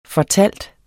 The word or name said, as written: fortæller